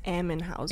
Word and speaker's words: Ammenhauser: On my left, I'm Riley Ammenhauser. – I don't know how to pronounce your last name. – It's Ammenhauser.